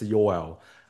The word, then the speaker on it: Yoel: You can pronounce it like Noel, but it's Yoel.